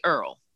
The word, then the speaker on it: oil: And so, you know, for instance, the word oil, O-I-L, you would pronounce it, you know, oil. Well, we say Earl.